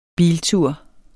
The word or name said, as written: BILTUREN